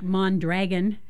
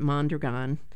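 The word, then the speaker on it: Mondragón: Which looks like Mondragon. – Yes, it looks like Mondragon, but they pronounce it Mondragon.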